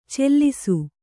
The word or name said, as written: ಚೆಲ್ಲಿಸು